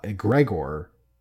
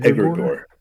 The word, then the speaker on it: Egregore: It's called the pronunciation kind of threw me a little bit because I know I call it Gregor, but it's pronounced like Egregor. – Egregore. – Egregor. – Yeah. – Yeah, it's like the cadence of it is not how Yeah. – my brain wants to say it.